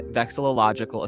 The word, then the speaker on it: vexillological: Well, I am here with Ted Kay, who is the secretary of the North American Vexillological Association. And I think I said that right. Is that right, Ted? – That's right. And that's the last time you need to say vexillological. It's NAVA for short. – N-A-V-A. Yes, the N-A-V-A.